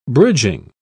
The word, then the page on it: bridging: British English